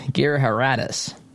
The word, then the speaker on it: Giridharadas: His first name is Anand, A-N-A-N-D. His last name is tough for me to pronounce. It's Giridharadas, I believe how you pronounce his last name.